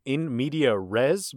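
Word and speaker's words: in medias res: in medias day. – In medias day? It's called... Is that you pronouncing in media res, but with a D instead of an R? – It's the accent.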